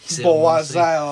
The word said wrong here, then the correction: Boissel: ramassé sur la palette de euh, c'est Boissel. Boissel, ouais. Avec deux S. Ouais, il y a deux S, t'as raison. Faut pas prononcer ça comme un Z dans ce temps-là.